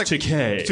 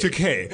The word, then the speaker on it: Takei: Yeah, we're like, it's pronounced Takei. – Takei.